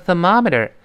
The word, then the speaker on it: thermometer: 最后那个 t 失去爆破了啊！Look at the thermometer，这个温度表叫 thermometer，th 呢要吐舌头，发成 th th th，后面 er 是 er 的声音，thermometer，m o 里面的 o 完全发成 r 的声音了，这美式英语的特色了。